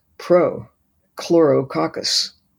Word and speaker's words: Prochlorococcus: It's Prochlorococcus. You can say it. – Yeah. Prochlorococcus.